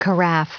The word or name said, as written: carafe